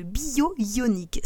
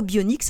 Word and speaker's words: bionique: Dans toute la série, on a une espèce de diphtongue qui arrive là où il n'y en a pas. En anglais, effectivement, bionic se prononce bionic. Mais en français, les traducteurs ont sorti de leur chapeau un espèce de bio-ionique. C'est n'importe quoi. Le mot bionique se prononce comme il s'écrit, bollocks and bullshit.